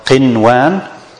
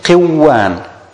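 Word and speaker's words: qinwan (قنوان): And the waw is one of the idgham letter. So we should pronounce it qiwan. Qiwan. But is that right? Is that correct? No. Because the idgham cannot be applied in one word.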